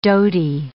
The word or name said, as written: dhoti